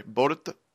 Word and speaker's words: Boruto: It's supposed to be, like, Bolt. – Yeah, like Bolt. The Bolt. – Yeah, but it's translated into B-O-R-U-T-O.